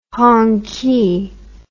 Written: Hongqi

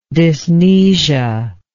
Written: dysmnesia

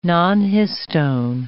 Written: nonhistone

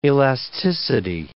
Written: elasticity

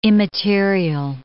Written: immaterial

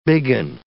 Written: biggin

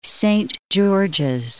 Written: Saint George's

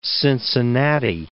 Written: Cincinnati

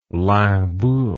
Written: limbourg

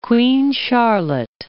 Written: Queen Charlotte Islands

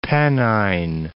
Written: Pennine Alps

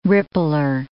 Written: rippler

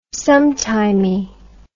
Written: sometimey

